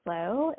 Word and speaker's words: SLO: I really love one called Slow. It's spelled S L O.